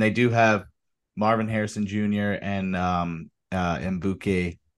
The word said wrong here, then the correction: Egbuka: Well, did I say his name right? – Emeka Egbuka. – Egbuka.